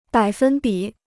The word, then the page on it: 百分比: bǎi fēn bǐ